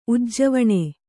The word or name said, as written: ಉಜ್ಜೀವನ